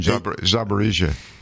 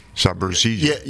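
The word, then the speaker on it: Zaporizhzhia: So the nuclear plant again in Ukraine, Zaporizhia. Zaporizhia.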